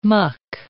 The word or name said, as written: muck